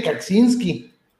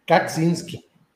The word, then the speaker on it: Kaczynski: No, no lo pronuncié bien porque para poder pronunciarlo bien tenía que meter la C bien. – Ah, pues te queda muy bien cesación. Cesación. – Por, eso yo sí le dije Kaczynski. Kaczynski.